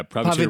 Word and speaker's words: Pavitr: Pavitr. – Yeah, Pavitr Prabhakar. But P-A-V-I-T-R.